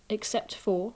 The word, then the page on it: except for: English